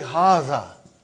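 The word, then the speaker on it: Haza (هذا): Haza. See, it is pronounced like Haza. But this alif is not written. It is explained in the key also.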